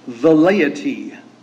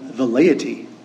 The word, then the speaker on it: velleity: I have to take care to pronounce it very correctly because you can mispronounce it very easily. It is the word velleity. V-E-L-L-E-I-T-Y. Velleity. Okay? Sounds a lot like velleity. But it's velleity.